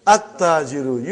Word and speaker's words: at-tajiru: At-Tajiru. You did not pronounce Lam. Why you did not pronounce? Because it is sun letter.